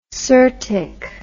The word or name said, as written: syrtic